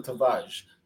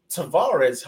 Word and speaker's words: Tavares: But, you know, Nuno Tavares is somebody where, in Portugal, that's how they would pronounce him. Tavares, however, is the name that most people in the UK will associate him with.